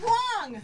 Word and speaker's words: Whang: It's Wong. Wong. I got the Wong pronunciation.